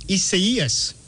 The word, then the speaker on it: Isaias: The correct pronunciation of the hurricane is Hurricane Isaias.